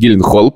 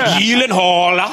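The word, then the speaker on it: Gyllenhaal: Это кусочек, где э, Джек Гилленхаль, то есть Гилленхолл, приходит на шоу, и они обсуждают, как на самом деле... Произносится его фамилия. Гилленхолл.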